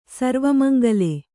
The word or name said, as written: ಸರ್ವಮಂಗಲೆ